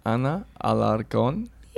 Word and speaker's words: Ana Alarcon: I've got Ana Alarcon.